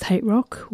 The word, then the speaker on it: HTRK: And at the top was HTRK, pronounced Hate Rock, with You Know How to Make Me Happy.